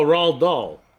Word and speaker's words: Roald Dahl: Road Dahl? – Yeah, I just closed the tab. I'm sorry. – Road Dahl? I have it right here. – Oh, Roald Dahl.